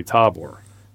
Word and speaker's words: Tabor: but there is like an accent over that A, meaning that first syllable should be emphasized. But it's really hard to change from, you know, you want to say Tabor, where it's actually Tabor.